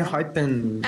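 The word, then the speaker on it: Enhypen: Inhypen? I don't know. I always say it wrong. – Inhypen.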